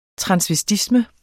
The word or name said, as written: transvestisme